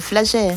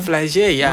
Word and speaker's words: Flagey: Je pense à la place Flagey, ça s'appelle Flagey. – Flagey, toi t'as bien prononcé.